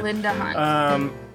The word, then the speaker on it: Linda Hunt: He says, "She does sort of look like Inda Hunt." Is that how you say her name? I don't know. From NCIS LA, but really... – I think it's Linda. – Oh yeah, Linda. It is Linda.